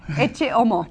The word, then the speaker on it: Ecce Homo: How do you say Ecce Homo? – Ecce Homo. – Ecce Homo, okay.